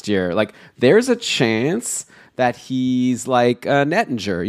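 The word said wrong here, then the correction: Oettinger: you know, like, or sorry, I keep saying it wrong. Ottinger, like last year, Ottinger hadn't played much.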